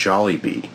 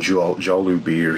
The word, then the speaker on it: Jollibee: This week's Twitter Theater, I'm still on the Jollibee train, and it's pronounced Jollibee, like it says clearly in the commercial, but last week I kept saying Jollibee or something.